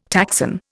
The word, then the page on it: taxon: Indian